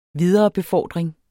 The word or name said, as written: viderebefordring